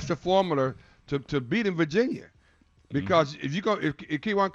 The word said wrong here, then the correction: Kihei: Clark, why did you pronounce his first name Keywon? – Kihei Clark. – Okay, I'm sorry. Kihei. Hey.